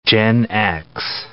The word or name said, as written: Gen X